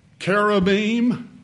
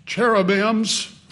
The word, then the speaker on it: cherubim: They're also in the Bible in the form of those two words that many people mispronounce, cherubim and seraphim. Familiar with those words? I've heard people say cherubims and seraphims, but the I-M on the end of those words in the Hebrew is plural already.